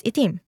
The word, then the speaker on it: itim: In standard Filipino, the word meaning black is itim, but in the Cebuano dialect, it is pronounced as itom.